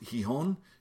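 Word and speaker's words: Gijón: I'm going to a place called, I believe it's pronounced Gijon, G-I-J-O-N,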